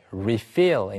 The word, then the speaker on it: refill: 발음은 사람에 따라 조금씩 달라서 refill이라고 하는 사람도 있고 refill이라고 하는 사람도 있는데 리필 자체 명사로 가리킬 때는 항상 refill이라고만 합니다.